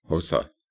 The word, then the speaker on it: Xhosa: And you have to do a click first. So it's Xhosa. Instead of trying to say the ex-Xhosa, it's Xhosa. And so the tribe into which Nelson Mandela would one day be born.